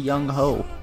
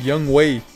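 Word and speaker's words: Younghoe: I am gonna go the Young Ho. – Yo, did you guys know that his name isn't pronounced Young Ho? – What is it, Young Who? – No, it was. It's like Young Wei.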